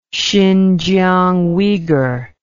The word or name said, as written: xinjiang uygur